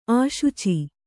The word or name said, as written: ಆಶುಚಿ